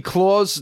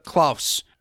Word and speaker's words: Klaus: So I asked Chuck earlier, Jesse, have you seen the movie Claws? It's just called Claws. It's on Netflix. It's Klaus. Yeah, technically, yeah, that would be the pronunciation, yeah.